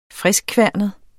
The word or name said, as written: friskkværnet